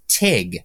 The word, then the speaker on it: tag: James Acaster, for some reason, pronounces the game tag as TIG.